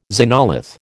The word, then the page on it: xenolith: American English